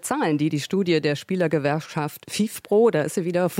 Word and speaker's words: FIFPro: Wie spricht sich das aus? – FIFPro ist komplett richtig.